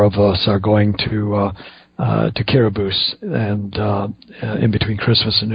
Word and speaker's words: Kiribati: It's spelled Kiribati, K-I-R-I-B-A-T-I, but it's pronounced Kiribati, because that island nation has 17% of its population is Latter-day Saint, and they have a high degree of malnutrition.